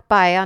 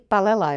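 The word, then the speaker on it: paella: Pak teda, jak jsme ještě zjistili, tak paeja, nebo já nevím česky, já tomu říkám vždycky palela, jo, ale... – Paeja se to vyslovuje. – Ale vyslovuje se to asi paeja, ale v Čechách tomu podle mě říkají všichni palela.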